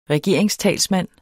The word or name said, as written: regeringstalsmand